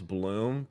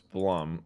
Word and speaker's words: Blum: Also, Kay, I used to think that Blum was Bloom, but I'm pretty sure it's Blum. Like the way you pronounce it. Steve Blum.